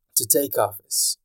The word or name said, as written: to take office